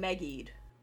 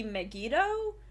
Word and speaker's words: Megiddo: I know we've been saying Megied over the course of our coverage. And I had assumed that Megiddo was just kind of the syllabic pronunciation of what they wanted. I feel like some people have subbed it Megied. But I think that we should, that it is actually supposed to be Megiddo.